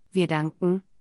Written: wir danken

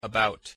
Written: about

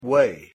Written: way

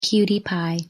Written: cutie pie

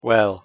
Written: well

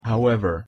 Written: however

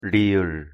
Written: ㄹ

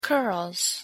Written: curls

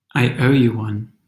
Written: i owe you one

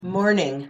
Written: morning

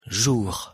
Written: jour

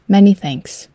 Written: many thanks

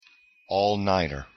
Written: all-nighter

